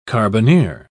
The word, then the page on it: carbineer: British English